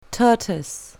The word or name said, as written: tortoise